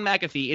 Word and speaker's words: McAfee: Is it John McAfee or McAfee? – This is the pinnacle of our relationship. Me fixing your mispronunciations. It is John McAfee. It is Amy Klobuchar. It is Pete Buttigieg.